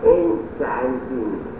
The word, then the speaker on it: enchanting: It is very enchanting, not enchanting, as per French pronunciation, creating all earth and all that essays, but as per English intonation, when N, C, H are in the same syllable, C, H is pronounced as essays, otherwise C, H is pronounced as chop, not shop.